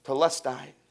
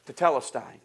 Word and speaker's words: Tetelestai: Jesus yelled, Telestai. What's the word? I can't say it correctly. Tetelestai.